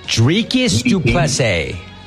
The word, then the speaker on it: Dricus du Plessis: How do you say his name? – Dricus du Plessis. – Du Plessis, yeah.